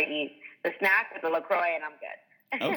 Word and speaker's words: LaCroix: I drink LaCroix, is that how you say it? – Yep. – Yeah, okay, I drink LaCroix, and I love to have whatever snacks are around.